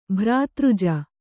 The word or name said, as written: ಭ್ರಾತೃಜ